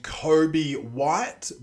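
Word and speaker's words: Coby White: The Bulls, Chicago Bulls got this dude named Kobe White, but it's spelt C-O-B-Y, not K-O-B-E. So it's not Kobe Bryant, but if you say it quick enough, Kobe White kind of sounds like Kobe Bryant.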